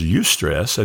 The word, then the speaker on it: eustress: There's eustress, and that's spelled E-U-S-T-R-E-S-S, and that's the good type of stress.